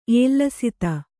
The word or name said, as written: ಏಲ್ಲಸಿತ